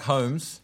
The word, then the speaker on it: Holmes: And Sherlock Holmes, what is the odd letter out there? The L in Holmes is silent.